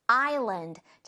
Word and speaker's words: island: 섬이 island. 자, 주의할 점은 여기에 S 스펠링 있죠? 이 S는 소리가 나지? 않아요.